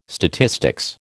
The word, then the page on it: statistics: American English